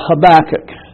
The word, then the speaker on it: Habakkuk: There's a book called Habakkuk. And I used to remember how to spell it because it's capital H-A-B-A-K-K-U-K.